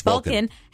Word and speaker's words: Vulcan: Yes, Vulcan.